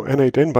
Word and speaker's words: n8n: And I think the the official way to pronounce it is now N8N, but it was Nodemation not too long ago.